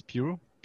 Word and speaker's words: Pugh: là je vais sûrement le, mal le prononcer, mais Florence Pugh parce qu'on prononce pense pas, je pense pas qu'on prononce le GH à la fin de son nom.